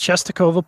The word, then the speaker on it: Częstochowa: I had to look at the pronunciation because, of course, it is spelled in Polish, which means there's a bunch of consonants stringed together. And I do not speak Polish, but it is Częstochowa, Poland.